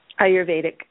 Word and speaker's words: Ayurvedic: the Ayurvedic, is that how it's pronounced? – Ayurvedic.